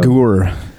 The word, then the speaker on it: Agur: Agur is how the Hebrew in my Logos program pronounces it.